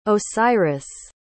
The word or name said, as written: osiris